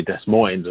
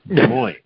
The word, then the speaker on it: Des Moines: I suppose I mispronounced that. It's not actually Des Moines, of course. It's Des Moines.